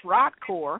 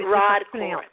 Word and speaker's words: Rodcor: Well, it's pronounced Rodcor. It's a little bit Rodcor. Thank you. – R-O-D-C-O-R.